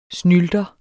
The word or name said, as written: snylter